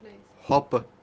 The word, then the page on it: hopu: kashmiri